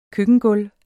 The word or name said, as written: køkkengulv